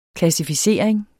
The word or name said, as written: klassificering